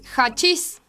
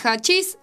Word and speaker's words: hachís: Vamos a la tercera, que es hachis, se escribe con H, pero suena más como una J.